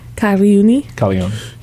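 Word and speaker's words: Calagione: If it's GLI, it's Lee. – Yeah. – So it's Caligione. – Is he Italian or Greek? – Yeah, Caligione. – That would be Italian. – Caligione.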